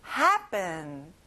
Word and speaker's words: happen: 자, P에 P 발음이 아니라 B 발음이에요. happen.